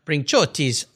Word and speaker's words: Brinciotti: Well, again, I'll make sure I put all these links on Luca Brinciotti's website page on the Carzio website. I'll spell his last name for you. B-R-I-N-C-I-O-T-T-I, Brinciotti.